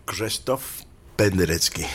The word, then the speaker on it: Krzysztof Penderecki: Escuchamos aquí en Raras Músicas Anaclasis para cuerdas y percusión, composición del año 1960 de ¿cómo se dice? – Krzysztof Penderecki. – Penderecki, mira, bien, Penderecki.